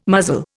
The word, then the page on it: muzzle: Indian